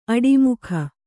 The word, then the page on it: ಆಡುಮೊಗ: āḍumoga